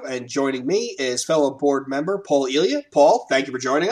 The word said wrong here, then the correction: Ilya: I just love that you continue the tradition of mispronunciations. I'm Ilya, but that's cool. – Ilya. Okay, so I missed the accent on the second syllable.